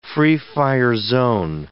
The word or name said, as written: free-fire zone